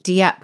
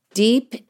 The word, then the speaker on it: DIEP: I don't know how you pronounce it in Canada, but in the States, they pronounce it deep. So a deep flap reconstruction. – Really? – Yes, yes. – I would have pronounced it Dieppe because it's actually spelled D-I-E-P.